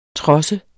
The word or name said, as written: trods